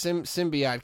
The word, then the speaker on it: symbiote: No, so Eddie Brock and this symbiote get together.